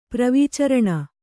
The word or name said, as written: ಪ್ರವೀಚರಣ